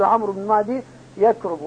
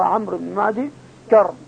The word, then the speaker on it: عمرو بن معدي كرب: لكن الناس يخطئوا يقول عمرو بن معدي يكرب وهذا خطأ، هو عمرو بن معدي كرب ولكن الياء لا تنطق فانتبهوا لهذا.